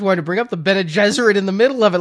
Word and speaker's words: Bene Gesserit: Where do you pronounce it again? – Jezebezeret? – Bene Gesserit. – Bene Gesserit, yeah. – Not Jezebezeret.